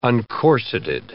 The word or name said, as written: uncorseted